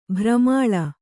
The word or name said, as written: ಬಿರುಮಳೆ